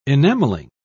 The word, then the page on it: enameling: British English